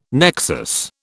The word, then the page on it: nexus: British English